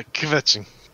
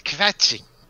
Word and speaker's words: kvetching: Yeah, kvetching. It's kvetching.